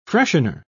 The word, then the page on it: freshener: British English